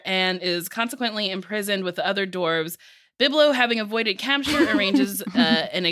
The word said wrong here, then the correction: Bilbo: Did I not say that right? Biblo? – No, I just love hearing it every time. – But I like Biblo. – Yeah. – Oh, Bilbo.